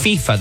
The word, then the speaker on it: FIFA: No, because FIFA is pronounced FIFA.